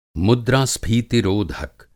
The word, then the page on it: मुद्रास्फीति रोधक: Hindi